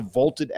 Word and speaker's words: VLTED: So go download the Vaulted app for free. It is spelled V-L-T-E-D.